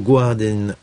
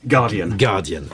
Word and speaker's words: Guardian: Nous sommes également en compagnie de John Henley, journaliste au Guardian en charge des affaires européennes. Je l'ai mal prononcé, on doit dire Guardian. – Voilà, on ne prononce pas le U.